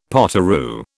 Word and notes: potoroo: British English

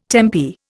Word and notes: tempi: Indian

